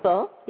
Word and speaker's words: Poulsbo: But if your listing is located in Poulsbo, did I say it right? On Bainbridge Island. – Poulsbo, yes.